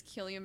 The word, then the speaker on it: Cillian: I didn't know it was pronounced Cillian, wow. Yeah. I have been pronouncing it Cillian for... – Yeah. I did too for years, and then I found out it was Cillian, and I felt like an idiot.